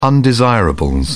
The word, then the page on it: undesirables: British English